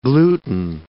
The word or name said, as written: gluten